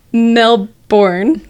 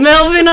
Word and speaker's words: Melbourne: She comes from Melbourne. I knew I was going to say it wrong. Melbourne, Australia.